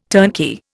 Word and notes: turnkey: Indian

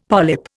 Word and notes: polyp: Indian

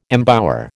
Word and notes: embower: American English

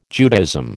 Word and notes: Judaism: American English

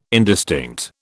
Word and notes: indistinct: British English

